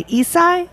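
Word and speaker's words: Issei: which is when the Nisei and the Isai, am I saying that right? – That's correct.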